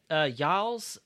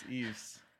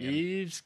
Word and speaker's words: Yves: Yals Guillemote. – I don't think that's right. – The first name is Yves, because it's like the cologne fashion brand. – Yves Guillemote.